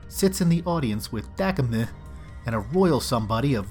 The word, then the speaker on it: Dakimh: They awake in mystical chains confronted by an enchanter, whose name is, and I may have this wrong, it's spelled D-A-K-I-M-H, which I believe is pronounced Dakimeh.